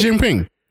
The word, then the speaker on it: Xi Jinping: Can you pronounce the name? You know how to pronounce Xi Jinping. – Okay, good.